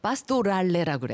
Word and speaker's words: 파스토랄레: 음악에서 파스토랄레라고 그래요. – 마스토랄레? – 파스토랄레. – 파스토랄레.